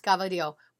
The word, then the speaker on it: cavatelli: You're telling me, Michelle Ungaro, that the cavatelli... – Drive over here, Michelle! Yeah. Get on the... – Cavatelli is spelled gavadio. I mean, it's pronounced gavadio. By a real Italian.